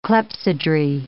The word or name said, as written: clepsydrae